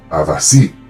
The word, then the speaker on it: abasí: La anarquía de samaba sucedió durante la época del califato abbasid o abasí en español, al fin la pronuncié bien en español, abasí, establecida por una dinastía descendiente del tío del gran profeta Mahoma, en la ciudad de samaba obviamente, de ahí sale el nombre.